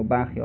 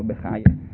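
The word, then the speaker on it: Bachya: Rabbeinu Bachia o Rabbeinu Bechaye, come alcuni pronunciano.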